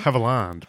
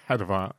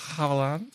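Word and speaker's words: Havilland: now, our second death, Olivia Mary de Hallevard. I believe I'm pronouncing that incorrectly. – Haviland, probably. – Haviland, probably. – Not Hadavar. I didn't know what she did the first time. – Haviland. Haviland.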